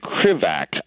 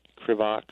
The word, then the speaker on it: Krivak: Andrew Krivak, I believe. K-R-I-V-A-K, Krivak, Krivak.